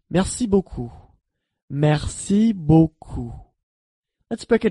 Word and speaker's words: merci: In French, thank you is merci. Merci. Let's break it down by syllable. Merci. Now let's hear it once again. Merci.